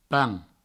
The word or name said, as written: pampan